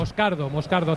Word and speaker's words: Moscardo: Moscardo, acento en la A.